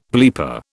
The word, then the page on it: bleeper: British English